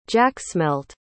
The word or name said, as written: jacksmelt